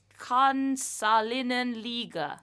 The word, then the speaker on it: Kansallinen Liiga: National League in Finnish is Kansallinen Liiga.